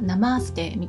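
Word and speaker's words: Namaste: はい、あのオムラジさんの方でね学びましたけどはい、発音はナマーステみたいですね。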